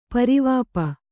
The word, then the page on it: ಪರಿವಾಪ: pari vāpa